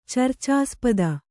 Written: ಚರ್ಚಾಸ್ಪದ

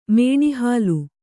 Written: ಮೇಣಿಹಾಲು

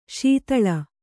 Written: ಸ್ಥಾಲಿ

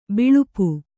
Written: ಬಲ್ಪು

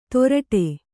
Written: ತೊರಟೆ

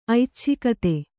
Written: ಐಚ್ಛಿಕತೆ

